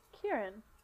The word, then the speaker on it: Kieron: How's it spelled? – K I E R O N. – Yeah, Kieran.